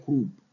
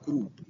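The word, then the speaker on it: croup: الاكيوت لارينجو تراكيو برونكايتس او لارينجيال كروب، هي بتنطق احيانا كرو البي سايلنت، وممكن تنطق كروب، يعني في ناس بينطقوها كده او كده.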